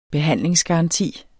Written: behandlingsgaranti

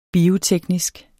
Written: bioteknisk

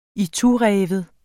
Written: itureven

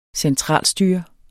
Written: centralstyret

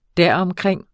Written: deromkring

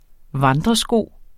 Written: vandresko

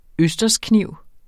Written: østerskniv

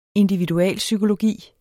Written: individualpsykologi

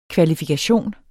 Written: KVALIFIKATIONERNES

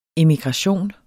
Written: emigration

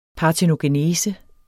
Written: PARTENOGENESE

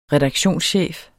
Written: redaktionschef

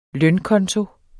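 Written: lønkonto

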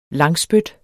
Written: langspyt

